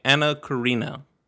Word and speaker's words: Karenina: So I was watching Anna Karenina.